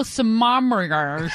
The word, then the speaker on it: sommeliers: There was an article in Washington Post magazine about how there are now sommeliers. That is actually how you pronounce it.